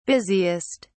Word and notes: busiest: British English

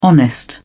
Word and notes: honest: British English

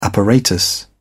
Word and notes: apparatus: British English

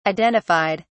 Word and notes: identified: British English

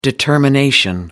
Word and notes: determination: American English

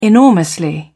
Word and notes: enormously: British English

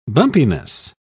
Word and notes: bumpiness: British English

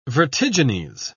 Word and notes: vertigines: British English

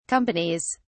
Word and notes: companies: British English

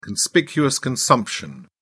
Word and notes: conspicuous consumption: British English

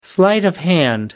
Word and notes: sleight of hand: American English

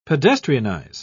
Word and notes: pedestrianise: British English

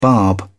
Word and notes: barb: British English

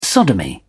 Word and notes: sodomy: British English

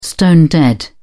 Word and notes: stone-dead: British English

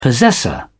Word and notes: possessor: British English

